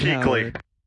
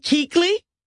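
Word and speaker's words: Kuechly: Keekly. – Huh, Keekly? Very Keekly. – Good. I didn't ask my producer how to pronounce that name. Luke Keekly? – Yes.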